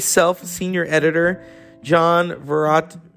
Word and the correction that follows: Varvatos: Varvatos, fashion designer.